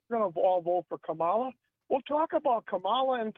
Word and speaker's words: Kamala: It's pronounced Kamala. – Yeah. – I do think it's been long enough now that we can all learn how to pronounce her name. – Yeah. – It's Kamala.